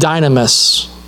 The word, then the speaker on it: dynamis: Matthew, Mark, and Luke will use a Greek word called dynamis. It's almost like dynamite, if you think about it like that.